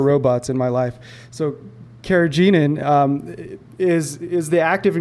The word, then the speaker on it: carrageenan: Um, it has a high carrageenan content. – I think it's carrageenan.